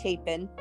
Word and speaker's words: Chapin: Am I pronouncing that last name? – It's a Chapin.